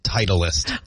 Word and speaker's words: Titleist: How do you say the company name? – It's pronounced Titleist.